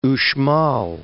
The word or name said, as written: Uxmal